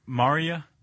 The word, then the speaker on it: maria: Maria? – Yeah, no, that sounds right.